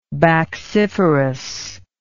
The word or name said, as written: bacciferous